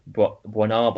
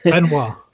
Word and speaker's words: Benoit: I'm going to go for Knives Out with Bernard Blanc. Bernard Blanc. How do you pronounce his name? – Benoit.